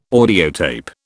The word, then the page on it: audiotape: British English